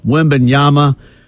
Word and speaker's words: Wembanyama: Wembanyama, the big 7'12", 8-foot, 10-foot tall guy.